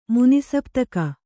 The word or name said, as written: ಮುನಿಸಪ್ತಕ